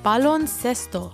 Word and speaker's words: baloncesto: In Spanish, basketball is called baloncesto.